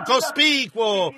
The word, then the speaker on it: cospicuo: Non c'era niente di cospicking in merito a ciò cospicuo, piuttosto, perché la Scrittura disse, non c'era bellezza perché lo desiderassimo.